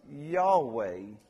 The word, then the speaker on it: Yahweh: Well, when it's all capital letters, this is the personal name of God. YHWH. We pronounce it Yahweh.